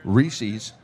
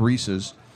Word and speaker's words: Reese's: Pronounced the proper way. Reese's, not Reese's.